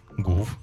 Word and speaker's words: Гуф: Гуф. Так и читается. – И Гуф. – Но не гю, не по-французски.